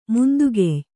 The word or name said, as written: ಮುಂದುಗೆಯ್